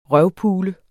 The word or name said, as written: røvpule